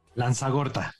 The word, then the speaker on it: Lanzagorta: Joan, dime si pronuncia adecuadamente tu apellido, por favor. – Lanzagorta, muy bien.